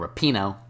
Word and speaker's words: Rapinoe: From Haaland, Zlatan, Messi, and Rapinoe, I pronounced it correctly this time.